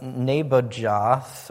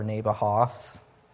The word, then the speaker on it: Nebajoth: the sister of Nebajoth," or Nebahoth, depending on whether J is silent, "to be his wife.